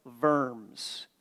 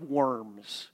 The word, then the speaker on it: Worms: Worms. It looks like Diet of Worms, which is not how it's pronounced.